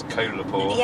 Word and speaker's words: Kolapore: I keep wanting to say Kolapore, but it's Kolapore. – Yeah, think of Coca-Cola. – Roller Cola. – Or a koala bear. I don't know why I think of that, but it helps me to pronounce it.